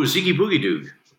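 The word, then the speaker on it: Zeeky Boogy Doog: E-E-K-Y space... B-O-O-G-Y space D-O-O-G. – Oh, Ziggy Boogie Doog. – Yeah. – Okay, I got it. – Yeah. Oh, it was no just problem. My pronunciation was off.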